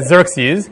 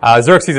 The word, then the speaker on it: Xerxes: The letter that's pronounced Kha in Greek is X, which Americans pronounce however they feel like it. So they pronounce it Xerxes.